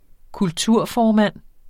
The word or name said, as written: kulturformand